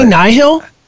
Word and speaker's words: Nihil: And it's so confusing because people ask, like, all the authors on Twitter how you say it, and they're like, it's like you say nihilism.